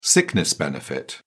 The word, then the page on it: sickness benefit: British English